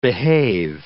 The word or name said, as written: behave